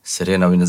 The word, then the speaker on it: Serena: No, in so naredili eno mega kampanjo, on glavni face, zraven je bila še Selena Williams. Serena, zakaj vse izgovorim?